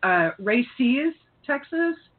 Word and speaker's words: Raices: And the name of the organization, and I always feel like I'm mispronouncing this, is Raices-Texas? – Yep.